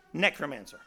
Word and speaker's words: necromancer: Necromancer? Necromancer.